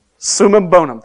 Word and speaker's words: summum bonum: In classical ethics, this desired outcome is referred to as the summum bonum. I don't know if I'm saying that right or not. I don't know Latin. How do you say that? Summum bonum, thank you.